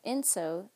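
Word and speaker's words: ensō: And Inso, that's spelled E-N-S-O, that is a circle that's drawn usually in one stroke, maybe two strokes of a calligraphy brush with ink and water.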